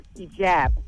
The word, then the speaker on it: hijab: The correct pronunciation of that head wrap is called hijab.